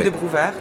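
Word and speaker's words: Desclée de Brouwer: Alors comment dites-vous ? Des Clés de Brouwer ? Il y a tellement de prononciations différentes. – Parfait, c'est parfait. – Des Clés de Brouwer, très bien.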